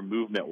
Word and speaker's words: MVMT: are movement watches. When you see it in ads or on Instagram or whatever, it's going to be spelled MVMT, but it's pronounced movement, was founded on the belief that style shouldn't break the bank.